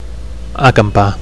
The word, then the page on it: acampar: Catalan